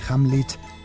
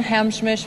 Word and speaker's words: Hamlisch: Hamlisch y Henry Mancini tuvo que corregirla. – And Marvin Hamsmish for Who? – The way we were.